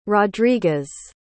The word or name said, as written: rodriguez